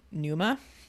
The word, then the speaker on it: pneuma: and in the New Testament is the Greek word pneuma. And you might recognize pneumas because it's spelled P with the silent P.